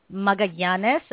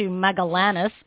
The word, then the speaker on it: Magallanes: I'm taking the double L and pronouncing it in Spanish, Magallanes, as opposed to Magallanes, which is what it looks like.